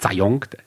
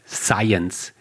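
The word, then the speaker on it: Zajonc: Science ähm, schreibt sich Zayong. Ja, vorne mit Z und hinten mit C. Der wird immer falsch ausgesprochen. Z der heißt nicht Zayong, Science. Robert Science.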